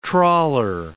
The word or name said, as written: trawler